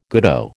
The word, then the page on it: goodo: American English